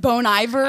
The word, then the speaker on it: Bon Iver: I said Bon Iver.